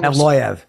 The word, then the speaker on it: Evloev: Evloev.